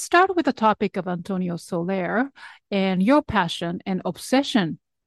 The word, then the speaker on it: Antonio Soler: Yeah, and then speaking of your scholarly work, I know you are deeply passionate about the music of Antonio Soler. Am I pronouncing okay? – Absolutely right.